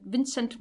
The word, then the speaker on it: Vincente: Vincent Minelli, wie ich neulich erfahren habe, wie er ausgesprochen wird, obwohl er Vincente geschrieben wird, was mich fertig macht.